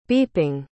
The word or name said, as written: beeping